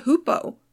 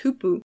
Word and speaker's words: hoopoe: It's a bird called the hoopoe, sometimes pronounced hoopoo, along with its relative, the wood hoopoe.